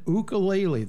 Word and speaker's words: ukulele: Now, you're pronouncing it ukulele. That is the correct pronunciation? – Yes, yes.